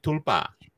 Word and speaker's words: tulpa: Some people say that's what you call a taupa. – Yes, a taupa. Oh. – So what's that? What's a taupa? – Not taupa. I think they can also call it tulpa.